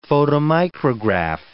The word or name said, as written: photomicrograph